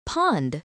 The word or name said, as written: pond